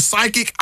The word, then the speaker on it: psychic: So you're right, because when we see psychic, it's spelled P-S-Y, right? – I couldn't tell you. – Yes, yeah. – So the P is silent.